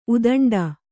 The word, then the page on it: ಉದಂಡ: udaṇḍa